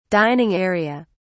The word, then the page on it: dining area: British English